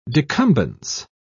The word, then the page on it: decumbence: British English